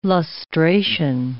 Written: lustration

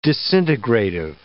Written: disintegrative

